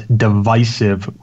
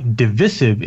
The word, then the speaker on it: divisive: Usage note, the word divisive is usually pronounced in both American and British English as divisive, with the stressed syllable having a long I. – Okay. I guess it in my corner now. – Although it is less favored than the pronunciation with the long I, the pronunciation with the short I, divisive, is on the path to becoming an established variant in English language.